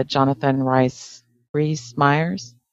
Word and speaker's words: Jonathan Rhys Meyers: But I've got kind of like an aversion to the lead actor, the Jonathan Rhys Meyers, however you pronounce it.